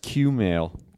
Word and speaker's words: Kumail: Kumail and I did a couple shows. We did shows in Portland and then Seattle. – I think it's pronounced Q-mail. – Q-mail. Sorry, Q-mail.